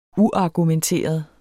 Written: uargumenteret

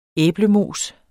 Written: æblemos

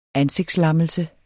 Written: ansigtslammelse

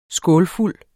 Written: skålfuld

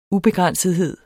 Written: ubegrænsethed

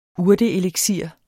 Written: urteeliksir

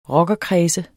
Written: rockerkredse

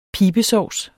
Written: pibesovs

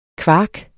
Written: kvark

